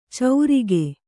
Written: ಚೌರಿಗೆ

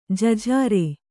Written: ಜಜ್ಜರ

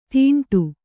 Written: ತೀಂಟು

